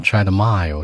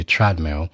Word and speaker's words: treadmill: 很多人愿意说成 treadmile，注意 treadmill。